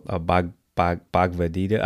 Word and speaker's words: Bhagavad Gita: And the book was, if I mispronounce the name, sorry, but it's Bhagavad Gita. – Oh, Bhagavad Gita.